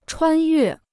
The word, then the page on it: 穿越: chuān yuè